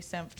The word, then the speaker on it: Senft: So mine's J Senft Photography, which is S-E-N-F-T, which is hard to pronounce and spell. No vowels.